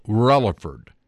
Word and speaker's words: Rutherfordton: It becomes F-A-R City. Ellenboro becomes Ellenburr. And Rutherfordton, believe it or not, it's spelled R-U-T-H-E-R-F-O-R-D-T-O-N. Rutherfordton becomes Rutherford.